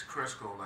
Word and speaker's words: Crisco: Oh, it's Crisco, like the grease.